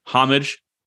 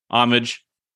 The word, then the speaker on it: homage: Refuting opinions on the pronunciation of H-O-M-A-G-E. After lots of Googling, it appears that there are multiple pronunciations that are accepted in the English language. There's actually three: homage, homage, and homage. I did some just loose polling of myself.